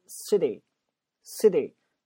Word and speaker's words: city: 你会发现你周围的美国朋友或你美剧里面学到的发音，那个 T 的发音多发成 D 的发音，这个元音我们之前解释过，T 夹在两个元音中间就变成 D，而且那个 City 那个 S 的发音，前面类似于有个声音的阻塞一样，后面突然爆发 City City，你感受一下。